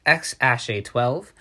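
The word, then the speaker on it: X Æ A-12: That is pronounced X-A12.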